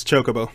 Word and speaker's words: Chocobo: Is it Jokobo or is it Kokobo? – It's Chocobo. – Chocobo. – It's Chocobo? – Yeah. – Okay. Yeah. It's a C, right? – Yep. You Chocobo. – Chocobo.